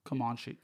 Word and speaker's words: Comanche: when it was in the Comanche language. And, like, is that what I'm saying that right? – Comanche.